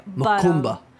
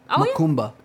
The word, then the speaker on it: Macumba: I think in like Brazil, they call it Kambale or something. – It's like Kambale. I don't even know how to say it. – Makumba. Makumba.